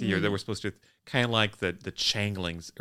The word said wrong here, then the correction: changeling: It's the Changeling.